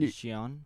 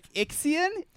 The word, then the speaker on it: Xi'an: or is it Xi'an? – That's a good reason to break in, though. – That I was impressed with your pronunciation. – I know, me too. – You weren't just like, Ixian? Ixian? Excuse me?